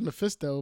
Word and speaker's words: Mephisto: Yeah, it is Mephisto, but it sounds funny when you say it like that.